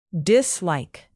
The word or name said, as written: dislike